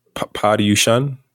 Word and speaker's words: Paryushan: Paryushan? – Yeah. – Is that how you pronounce it? – Yeah. You got it.